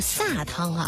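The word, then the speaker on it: 糁汤: 但是呢，好像在我们安徽当地啊，包括像河南一些地方的这个语境当中哈、啊，可能大家还是会经常念成是撒汤啊。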